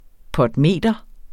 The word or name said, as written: potmeter